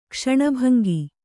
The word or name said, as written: ಕ್ಷಣಭಂಗಿ